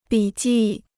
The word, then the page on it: 笔记: bǐ jì